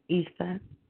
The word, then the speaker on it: Isa: I think the proper way to say the name is Isa.